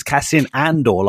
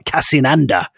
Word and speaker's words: Cassian Andor: Who's this silver fox who pronounces Cassian Andor like Cassian Ander?